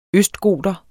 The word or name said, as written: østgoter